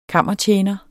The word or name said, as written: KAMMERTJENERE